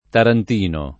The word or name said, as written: tarantino